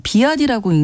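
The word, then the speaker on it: BYD: 알파벳으로 BYD라고 써있는 비아디라고 읽는 중국의 전기차인데요.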